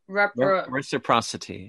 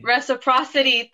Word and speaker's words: reciprocity: Reciprocity? – Yes, thank you. What I forgot one syllable. – Reciprocity. – Reciprocity.